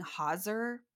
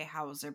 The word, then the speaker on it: Hauser: I saw some places that people were saying Hauser, but I'm going to say Hauser because I feel like that was the most common pronunciation.